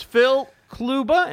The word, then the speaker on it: Kluba: Phil Kluba, am I pronouncing that correctly? Which I should have asked you in the pre-show. – Yes, you are. You actually are pronouncing it correctly.